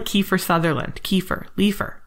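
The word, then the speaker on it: Leafar: That's Leofar. I looked it up. That's how it says I'm supposed to pronounce it.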